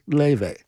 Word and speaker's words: Laufey: For Squilly, here's Leve, spelled L-A-U-F-E-Y, but pronounced Leve.